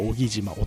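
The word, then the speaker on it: 男木島: 荻島男に木と書いて荻島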